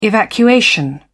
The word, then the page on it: evacuation: British English